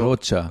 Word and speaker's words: Rocha: Also Rocha. Rocha, also schon mit, Sch, aber langsam ausgesprochen. Ein langsames, langes O.